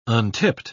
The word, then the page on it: untipped: British English